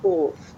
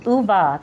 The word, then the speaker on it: Ove: Is that, I always pronounce that right, or is it, was it Uwe? – It was Uva.